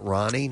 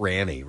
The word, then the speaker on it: Rani: Goldie Hawn and her daughter Kate Hudson, with Kate's daughter Ronnie, spelled R-A-N-I. Is that how you would pronounce that? Ronnie? Not Ranny, right? – Ronnie. Just like Ronnie says.